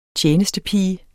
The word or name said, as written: TJENESTEPIGERNES